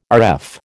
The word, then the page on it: Rf: American English